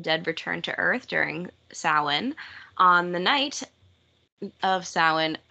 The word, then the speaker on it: Samhain: Yeah, so it is spelled Samhain, S-A-M-H-A-I-N. – Yes. Okay, right. I did know. – Samhain. – Yeah, I guess I did know. – So if you've ever read about it, then you know, but if you've never heard it pronounced, you probably didn't.